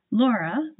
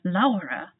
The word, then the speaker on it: Laura: I live in Germany and people often ask me the right way to say my first name, Laura, like in English, or Laura, like in German.